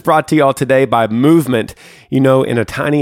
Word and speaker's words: MVMT: Movement, which is spelled MVMT, grew into one of the fastest growing watch brands, shipping to over 160 countries across the globe.